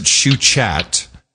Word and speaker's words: Schuchat: Dr. Anne Shuchat, S-C-H-U-C-H-A-T. You might pronounce it Shuchat. S-C-H-U-C-H-A-T.